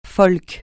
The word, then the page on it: folk: francais